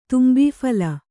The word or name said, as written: ತುಂಬೀಫಲ